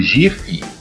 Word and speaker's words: GIF: Que é a pronúncia do, do, do GIF e que, segundo o criador do formato, é GIF.